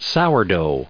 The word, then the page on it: sourdough: sour*dough; American English